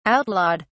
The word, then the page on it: outlawed: British English